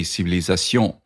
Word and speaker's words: civilisation: Like the word civilization has two stresses in English. The strongest stress is on the A vowel, but it has a secondary stress on the first syllable, civilization. In French, you really just say civilisation without a whole lot of secondary stress.